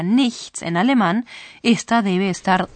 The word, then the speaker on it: nichts: Scusi, ich nix verstehen. – ¿Será porque no ha pronunciado correctamente la palabra nada? Nichts. – No es eso. En el lenguaje coloquial se pronuncia así con frecuencia.